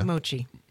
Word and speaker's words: mochi: Mochi. – Is that how you pronounce it? – Yes.